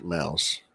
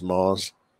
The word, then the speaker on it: Maus: But I remembered that it's M-A-U-A-S. No, M-A-U-S, the German spelling of mouse, pronounced mouse. – Right. – poor Nick. He used to always think it was pronounced Moss. And I would argue with him. We had an argument on air. It's like, Nick, it's pronounced mouse.